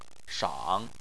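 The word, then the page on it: shang: tone 3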